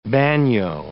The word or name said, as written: bagnio